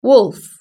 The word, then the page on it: wolf: British English